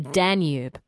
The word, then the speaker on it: Danube: And he says it's a slight emphasis on the Dan part. So Danube. He says, at least this is how we seem to pronounce it in Australia.